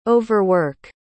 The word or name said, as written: overwork